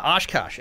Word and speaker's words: Oshkosh: He knows how to say Oshkosh, or he was coached to say it. – That's right. – He said it correctly. – Yeah, Oshkosh.